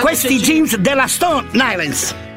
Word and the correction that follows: Stone Island: Ma perché scrivono? Scrivono come si legge. – Si chiama così, Stone Island.